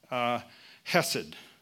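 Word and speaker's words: chesed (חסד): It's a word called chesed. And chesed is sometimes it's spelled with an H. It's one of those funny words that if you, it sounds more like a camel trying to get a hairball out of its throat, because you're supposed to do a real hard sound.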